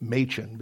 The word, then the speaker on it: Machen: Macon, I've always pronounced it Macon, I guess, but I think I've been corrected through some listening on sermon audio, because everybody has been pronouncing it Machen, because his name is spelled M-A-C-H-E-N, who left Princeton University.